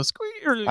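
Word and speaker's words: SQream: It's SQ, like SQL, and then Ream. So Scream. – I think it's Scream.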